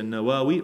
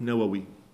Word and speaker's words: Nawawi: Och man kan uttala det här på två olika sätt. Man kan säga Nawawi och Nawawi. Han föddes år 631 hijri.